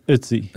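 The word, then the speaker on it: Ötzi: Does Oatsy have an umlaut or not? – Yes, it's Oatsy. – Okay. – It rhymes with Tootsie, I saw someone put it. I think our good friends at Smithsonian Magazine. – Yeah, Oatsy. There's a bit of an R in there.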